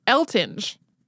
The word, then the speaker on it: Eltinge: There are numerous print sources from when he lived that insist that it was pronounced Eltinge, like with a hard G. But there is a lot of old footage floating around, as well as more recent footage of people talking about him where people say it Eltinge.